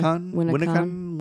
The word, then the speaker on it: Winneconne: Hey, Google. How do you pronounce Winneconne, Wisconsin? – That's pronounced Winneconne, Wisconsin.